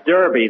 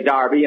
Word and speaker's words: Derby: Bill, that's Derby. The English say Derby, and the Americans say Derby.